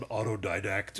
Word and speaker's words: autodidact: Unless you're just an autodidact who... Did I even pronounce that correctly? – Yes, you did.